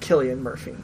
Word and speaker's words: Cillian: It is, in fact, Cillian Murphy. – Okay. Well, he is less silly than Killy in his roles, and so we can probably remember that.